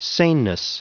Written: saneness

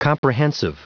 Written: comprehensive

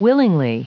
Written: willingly

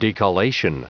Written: decollation

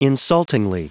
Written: insultingly

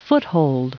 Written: foothold

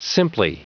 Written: simply

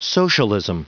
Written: socialism

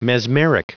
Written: mesmeric

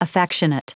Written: affectionate

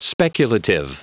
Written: speculative

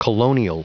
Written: colonial

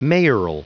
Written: mayoral

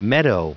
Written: meadow

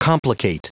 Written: complicate

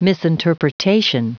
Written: misinterpretation

